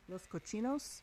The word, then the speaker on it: Los Cochinos: Before that, Little Cheech and Chong from their Los Cojinos album. Am I saying it right? Los Cochinos, a couple bits, the Strawberry Revival Festival, and Don't Bug Me, some 70s classics right there.